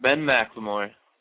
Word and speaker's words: McLemore: How do you say that? – Ben McLemore. – McLemore. Well, it's not spelled McLemore. That's where it throws me off at.